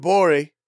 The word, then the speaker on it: Gborie: The G is silent on Twitter. – Yes, sir. – Cool Guy Jokes 87 on Instagram. – 100%. – There he is. – David Gborie.